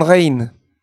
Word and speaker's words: Rijn: C'est comme une Il s'appelait Rembrandt van Rijn. – Ah. – En van, van, V-A-N, et R-I-J-N, qui se prononce R-I-N.